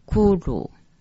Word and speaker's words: kuru: 二十七句，巨炉两个字念成 kuru。